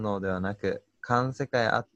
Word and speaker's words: 環世界: 生物たちが独自の知覚と行動とつく行動で作り出すこれ、環世界って読むんですかうん。環世界ですね。